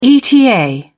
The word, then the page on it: eta: British English